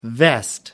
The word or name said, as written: vest